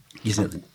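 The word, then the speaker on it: Ghiselin: Nasıl okunuyor? Gizel'in mi? Gizel'in evet. E, topluma bakışımızı düzeltecek hakiki bir cömertlik örneğiyle karşılaşmadığımızı görürüz işbirliği denen şeyin fırsatçılık ve sömürü karışımı olduğu anlaşılır.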